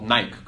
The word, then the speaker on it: Nike: The guy's uncle works for a company called Nike, here in Israel called Nike, because we don't pronounce the E.